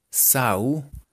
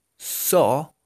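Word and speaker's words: saw: Sin, ¿sí S-E-E-N. E e Entonces recuerden, escrito sonaría así. Se, sau, sen. Ok. Pero pronunciado de manera correcta, de manera gomelita. Si, so, sin. Sí, so, sin.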